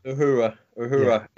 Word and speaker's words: Uhura: Among the dead was Thomas Nichols, brother of the actress Nichelle Nichols, who is best known for her role as Uhura? – Uhura.